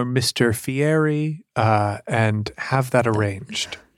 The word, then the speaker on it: Fieri: Guy Fieri. We're supposed to pronounce it Fieri.